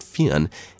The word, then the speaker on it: Fionn: There's a lot of different spellings, and in some places he's called Finn McCool, but I've been assured Fionn is approximating the correct Irish pronunciation.